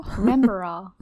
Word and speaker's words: Remembrall: Remembrall.